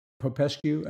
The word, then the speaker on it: Popescu: But my personal view is that there's several mentions in the movie, particularly by people like Popescu. Am I pronouncing his name correctly? – I think so.